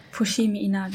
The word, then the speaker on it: Fushimi Inari: Nee, wir waren nur im Fujimi, Inari. – Ah, ja, also ich habe das bestimmt falsch ausgesprochen, aber. Fushimi Inari.